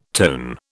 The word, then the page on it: tone: British English